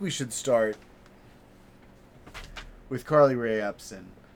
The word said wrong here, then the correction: Jepsen: With Carly Rae Jepsen, I'm sorry.